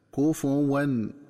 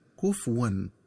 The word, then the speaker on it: كفواً: وكذلك ينبغي التنبه للنطق الصحيح لكلمة كفواً، فهي تقرأ بضم الفاء هكذا: كفواً، ولم يكن له كفواً أحد. ومن الخطأ قراءتها بتسكين الفاء هكذا: كفواً، ولم يكن له كفواً أحد.